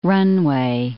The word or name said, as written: runway